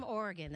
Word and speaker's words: Oregon: He's from Oregon.